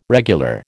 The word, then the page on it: regular: American English